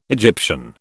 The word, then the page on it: Egyptian: British English